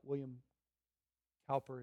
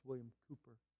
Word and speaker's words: William Cowper: His name is not William Cowper, it's William Cooper.